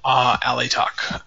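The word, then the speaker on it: Alaitoc: Altioch is the Ranger one. – Oh, Alatok. – Alatok.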